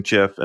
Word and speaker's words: GIF: And I don't know if you use GIF just to be polite to me or not, but people are really mad. – No, you and I are Team Soft G for life.